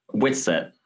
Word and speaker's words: Whitsett: Whitsett.